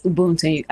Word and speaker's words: Ubuntu: Yes, yes. Ubuntu. I think you said it right.